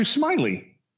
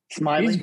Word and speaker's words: Smyly: Oh yeah, it's Drew Smyly. Smyly.